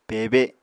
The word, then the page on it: pebe: Apatani